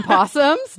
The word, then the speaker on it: possums: Yeah, apparently She writes opossums because this is a very regional thing, but in the South, we still call them possums. – The O is silent. – Yeah, you guys say possums, but there's an O at the beginning, which is so weird to me.